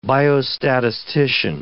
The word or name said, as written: biostatistician